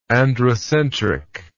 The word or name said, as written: androcentrist